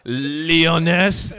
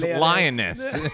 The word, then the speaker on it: Liones: L-I-O-N. E-S. – Okay, yeah, that's... – She knocked out one S. – Yeah. Yeah, I like how you're pronouncing it like it's not a female lion. You're like, Leoness. No, don't make it all French. It's Lioness.